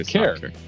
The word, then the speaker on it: Kerr: Jessica and I used to work together, and as I recall, it's a long time ago, I think she introduced herself to me as Care Like Care Bear. So I think that's how it's pronounced, but she'll correct me.